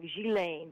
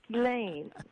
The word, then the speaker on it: Ghislaine: So, I mean, I thought it was, you know, more French, like Ghislaine, but no, it's Ghislaine.